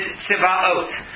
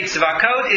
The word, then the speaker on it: Tzivaot: She used the name Tzadi Bet Aleph Vav Taf, which if you read it literally and pronounce it properly is Tzivaot. The reason I say Tzivaot